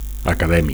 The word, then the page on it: acadèmic: Catalan